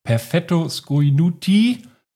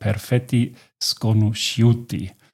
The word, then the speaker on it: Perfetti sconosciuti: Perfetto sconuti, so steht es hier, wird ausgesprochen perfetti sconosciuti.